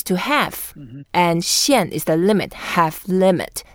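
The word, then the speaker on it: xian (限): Xian. – Xian. That's the way to pronounce it. It's the fourth tone, so we go down.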